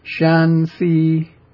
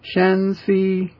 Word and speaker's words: Shanxi: Shanxi. Shanxi. Two even-pitched syllables.